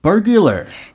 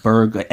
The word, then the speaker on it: burglar: Not burger, like hamburger. burger. L 하나 더 저기 들어가니까 예.